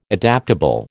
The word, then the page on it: adaptable: American English